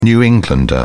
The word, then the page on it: New Englander: British English